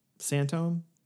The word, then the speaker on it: sinthome: Santome? – Yeah, that's maybe definitely true. Yeah. – You know, and so, and that for the listener, that's how you pronounce the thing that looks like synthome, or S-I-N-T-H-O-M-E.